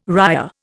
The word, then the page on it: ria: Indian